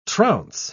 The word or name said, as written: trounce